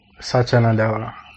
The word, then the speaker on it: Satya Nadella: Satya Nadella.